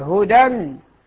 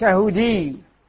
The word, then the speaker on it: هدى: على الألف، أحسنتم، ضمة مقدرة، لا، ليس على الياء، هدى، ليس هدي.